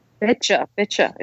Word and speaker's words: Beccia: Beccia, isn't it? – Yes, it's pronounced like you betcha.